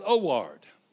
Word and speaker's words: Howard: My name is Howard. In Spanish, H is silent. My name in Spanish is Oward.